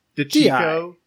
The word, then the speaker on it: DiCicco: How would you pronounce that? DiCicco? – DiCicco. – DiCicco, that makes sense.